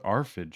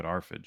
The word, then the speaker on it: RFID: But they're more expensive because we put ARFID chips in them. – Is that how? I thought it was RFID.